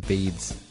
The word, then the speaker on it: Beads: Oh, I'm sorry. I've mispronounced that. It's actually Song from Beans. No, Song from Beads.